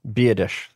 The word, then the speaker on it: Biadasz: Biadasz. That's how you say his name.